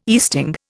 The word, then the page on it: easting: Indian